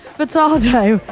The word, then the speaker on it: Furtado: Can't even say her name. Furtado. Say it right.